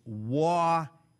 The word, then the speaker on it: Waukesha: Waukesha is Waukesha. Emphasis on first syllable, short E on second syllable. Waukesha.